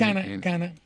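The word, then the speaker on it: Canha: Caña, no sé si se pronuncia Caña, pero es Cana.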